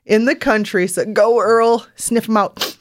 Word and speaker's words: Earl: His name is URL, and it's actually pronounced Earl.